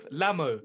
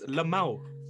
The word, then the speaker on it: LMAO: Lamo. What is that? It's Lamau. – Lamau. – It's not Lamo. Can you read? L-M-A-O.